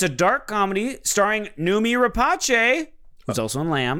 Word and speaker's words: Noomi Rapace: Also, I looked at the pronunciation. It's Noomi Rapace. – Oh, it's Noomi Rapace. – Mm-hmm. – Not Rapace. No. Noomi Rapace.